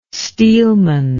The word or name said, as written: steelman